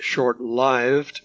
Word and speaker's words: short-lived: By the way, I insist on pronouncing that short-lived because it derives from the word life, which we do not pronounce lif.